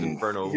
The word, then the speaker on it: Inferno: For those who don't know what Inferno is, Inferno. – You've got to roll your R's, dude.